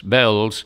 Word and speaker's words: Belles: They're an American girl group by the name of Bells. That's spelled B-E-L-L-E-S, Bells.